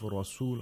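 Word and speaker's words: رسول: The ra of rasul, on the other hand, is pronounced with tafkhim, with thickness, with a full mouth. War rasul.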